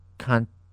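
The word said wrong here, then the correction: Cantó: Where'd you get that extra syllable? – I don't know. – It's canto. – Yeah. But like, I'm seeing a I in between the. – It's got a accent.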